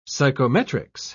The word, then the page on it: psychometrics: British English